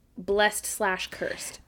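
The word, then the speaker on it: Blessed-Cursed: Next up, we have In Hypen with Blessed / Cursed. Is it Blessed / Cursed? I think it's Blessed Cursed. I don't think you pronounce the hyphen.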